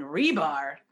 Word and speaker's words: Hribar: Renee, people love to misspell that, R-E-N-E-E. And Rebar, that H is silent. Every time I go to any, you walk up to a desk and you're here to see and your last name is, I don't even say it because if I say it, they're going to say an R first. The H is silent. H-R-I-B-A-R.